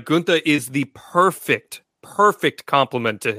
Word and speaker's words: Gunther: So the German pronunciation is Gunther.